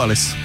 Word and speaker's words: Alice: Jak jste vyslovili? Je to Alice nebo Alice? – Tak je česká, tak asi Alice, ne? – Tak Alice.